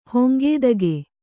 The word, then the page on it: ಹೊಂಗೇದಗೆ: Kannada